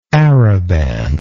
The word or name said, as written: araban